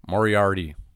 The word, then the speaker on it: Moriarty: Moriarty.